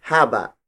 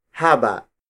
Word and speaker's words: haba: When it comes to length, the root word for it is haba. Haba. Haba. Haba is spelled as H-A-B-A.